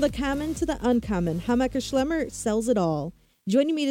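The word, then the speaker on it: Hammacher Schlemmer: Well, you say it Hameker Schlemmer.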